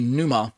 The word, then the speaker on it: pneuma: or if you don't want to pronounce the P, just say pneuma.